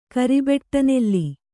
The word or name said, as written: ಕರಿಬೆಟ್ಟನೆಲ್ಲಿ